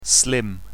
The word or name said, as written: slim